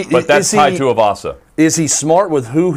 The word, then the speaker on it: Tai Tuivasa: Uh, Tai Tuivasa he is fighting Greg Hardy.